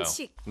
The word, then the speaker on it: 반칙: 칙이 조금 어려울 수도 있을 것 같긴 해요. – 칙. – 이게 뭐 칙칙 봄 봄. 마모사 노데츠 원넷 봄. – 네, 근데 칙 발음이 좀 어렵네. – 괜찮은 것 같아요. – 반칙. – 반칙. – Like chick. Chick with a chick.